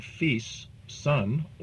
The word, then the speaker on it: fils: The spelling F-I-L-S could represent the word fils, son, or the word fil, wires.